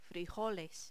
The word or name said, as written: frijoles